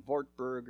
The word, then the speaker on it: Wartburg: And so for many months, he spent time in the castle of Wartburg, or if you're German, Wartburg, or, you know, they pronounce it differently.